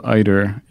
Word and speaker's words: eider: Spectacled eider, E-I-D-E-R.